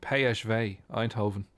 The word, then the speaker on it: PSV Eindhoven: Manchester United's meeting with, if we say it properly, PSV Eindhoven is the feature game on RT2 television tonight.